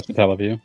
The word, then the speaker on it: Satellaview: The Satellaview games? – Yeah, Satellaview.